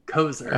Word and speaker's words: Koser: It's pronounced with a Z, Koser.